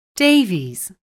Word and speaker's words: Davies: Davies. – Oh, can you spell that, please? – Yes, it's D-A-V-I-E-S.